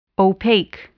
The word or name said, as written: opaque